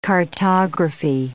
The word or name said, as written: cartography